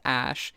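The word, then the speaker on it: Æ: The sort of AE symbol, which is normally pronounced Ash, is her elven spelling of AI, love and or artificial intelligence.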